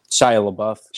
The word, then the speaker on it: Shia LaBeouf: Dustin Hoffman, John Voight, Shia LaBeouf or LaBeouf. I'm not sure how you say his name. He's pretty good, too. – Shia LaBeouf.